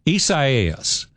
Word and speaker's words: Isaias: Isaias is the name of the hurricane.